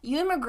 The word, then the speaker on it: Ewan: Ewan. – Ewan McGregor, who plays, Frank Churchill.